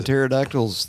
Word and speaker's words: pterodactyl: And pterodactyls, they've got a silent P.